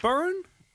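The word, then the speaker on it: Beirne: We are joined on it now by Hilary Byrne.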